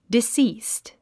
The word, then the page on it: deceased: disíːst; ディスィースト